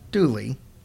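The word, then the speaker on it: Dula: A man named Tom Dooley pronounced Dooley, but it's D-U-L-A is how it's spelled, but it's pronounced Dooley, was convicted and hanged for the crime.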